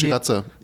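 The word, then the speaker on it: Sriracha: Wie spricht man die? – Shiraza.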